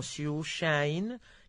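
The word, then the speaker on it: Sciuscià: La pronunciación en inglés suena como Shushain, que en napolitano se convirtió en Shusha, que es el título en italiano, el título original de esta película.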